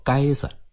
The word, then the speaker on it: 街子: 街子在当地方言中读作街子，保留着一条条明清时期的街道和店铺，总有一两百年的历史。